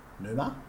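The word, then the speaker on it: Pneuma: So for me, track two, Numa. – Numa. – Yeah. Silent P.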